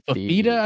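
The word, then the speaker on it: Fifita: They keep Noah Noah Fertitta? Is that how you pronounce his last name? – Fafita, I believe. – What is it? – I think it's Fafita. – Fafita, yeah. He's really good, and they found something with him.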